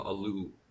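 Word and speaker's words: Oluo: And, like, I've been thinking about this myself, like, and especially reading, I'm still going to pronounce her name incorrectly, Ijeoma Alu. Oh, I think? Okay, not Alua. I said Alua the first time.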